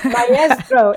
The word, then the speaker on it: maestro: Maestro is how you pronounce maestro. It drives me crazy, but so, that's okay. We live in the U.S., so exactly. – Not everybody is going to be able to trill their R's, but it's maestro.